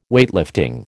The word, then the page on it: Weightlifting: American English